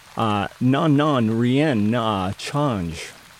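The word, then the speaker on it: Non Non Rien N'a Changé: Les Poppies, Non Non Rien Na Change. That is how you pronounce that, from their self-titled album by Les Poppies.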